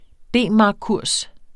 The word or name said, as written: D-markkurs